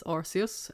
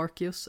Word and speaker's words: Arceus: Och sen så har vi naturligtvis också börjat spela Pokémon Legends Arceus, eller Arceus, eller hur man nu vill uttala det. – Beroende på vart man kommer ifrån, verkar det vara. – ja, det verkar vara väldigt eh, varierande där.